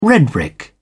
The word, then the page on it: red-brick: British English